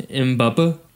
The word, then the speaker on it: Mbappe: It's pronounced Mbappe. – Mbappe? Sorry, Mbappe.